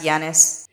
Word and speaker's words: Giannis: It's pronounced Giannis.